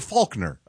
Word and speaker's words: Faulkner: There's no L. – I don't pronounce. You don't pronounce. You say Faulkner.